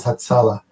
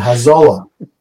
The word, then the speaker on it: Hatzalah: They couldn't pronounce Hatzala. So they said Hazola.